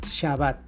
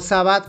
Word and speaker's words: Shabbat: No Sabbat como algunos dicen, Sabbat, porque bueno, en español la, la H no se pronuncia, pero Recuerden que eh, viene del hebreo y en hebreo la S con la H se pronuncia sh, Shabbat, no Sabbat.